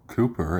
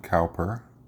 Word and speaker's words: Cowper: Today we are talking about William Cooper, it's pronounced, not Cowper, apparently.